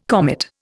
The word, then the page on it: comet: Indian